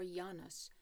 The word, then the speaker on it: Janus: The month of January was named for the Roman god Janus, or Janus, to give it the Latin pronunciation.